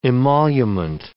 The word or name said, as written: emolument